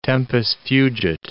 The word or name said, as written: tempus fugit